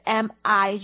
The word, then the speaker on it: 秘: 秘密是 mo 发音，那个拼音的话呢是 mi，就是 mo e mi。